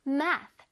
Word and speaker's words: math: 자, MA 부분에서 광세를 줘서 우리 한번 발음을 해야 되고요. math. 그 다음 TH 부분을 어떻게 발음해야 되냐면, 자, 실로 튕기듯이 발음할 거야. 어떻게? 자, 윗니, 아랫니의 혓바닥을 넣었다가 슉 뺄 거예요. math. math. 오케이. 잘 들었어요? 살짝 바람 소리도 나지요?